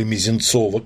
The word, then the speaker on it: Мезенцов: Ну, например, известное покушение, такой своеобразный пролог, можно сказать, народнического, а за ним, соответственно, эсеровского террора, это убийство шефа жандармов Мезенцева, или Мезенцова, как было принято ставить, ударение произносить в 19 веке, 4 августа 1978 года.